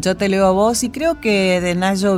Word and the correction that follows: Natxo: Se escribe N de A T X O. Nacho Vidal.